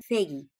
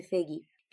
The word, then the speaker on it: Foege: Él se llama William Feggy. Su nombre se escribe bien raro porque se escribe Foege, pero se dice Feggy, que dice The fears of the rich and the needs of the poor.